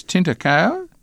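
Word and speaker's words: Tinta Cão: It's Tintacao? – Correct.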